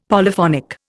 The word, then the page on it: Polyphonic: Indian